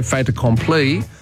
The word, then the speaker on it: fait accompli: So if you just said fate by itself, it's F-A-I-T, fate, you don't pronounce the T. If you say fait accompli, you do pronounce it because the consonant links onto the vowel at the start of the next word.